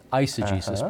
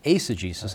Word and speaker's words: eisegesis: I know some people pronounce it eisegesis, but technically it's pronounced eisegesis, and that is taking man's ideas and trying to make them fit into the Word of God.